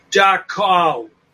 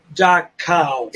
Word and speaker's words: Dachau: We'll be talking about Dachau because Dachau is another one that people I got to pronounce it right. It's Dachau. Dachau. – We're going to say Dachau just because we're English speaking.